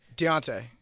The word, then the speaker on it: Deontay: Is it Dante? – Deontay. – But there's no E. – Yeah, Deontay Foreman. – The E is the apostrophe. – You know, you pronounce the E and D.